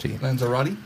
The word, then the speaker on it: Lanzarote: Lanzarote.